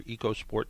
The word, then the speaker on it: EcoSport: The recall encompasses about 140,000 vehicles, including the 2016 to 2018 Ford Focus, 2018 to 2022 Ford EcoSport or EcoSport, depending on how you say it.